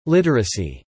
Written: literacy

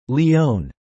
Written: Lyon

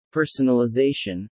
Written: personalization

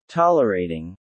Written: tolerating

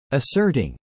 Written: asserting

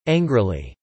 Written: angrily

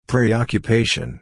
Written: preoccupation

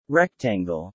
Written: rectangle

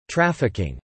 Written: trafficking

